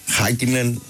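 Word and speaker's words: Häkkinen: Τους Φιλανδούς πάντως τονίζουμε στην πρώτη συλλαβή. Οπότε έχω λέω καν στοιχηματική, ειδικά με αυτό έχουν κόλλημα. Ναι. Δηλαδή. – Για πες μας, μίλα μας. – Είναι Μάρκανεν, Χάκινεν.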